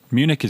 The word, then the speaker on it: Munich: Munich is good.